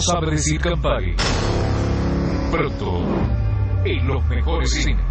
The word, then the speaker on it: Campari: No sabe pronunciar Campari. – Sí, ¿qué le sirvo? – ¿Me das un Camperi? – ¿Un qué? – Un Campana. – ¿Perdón? – Campari.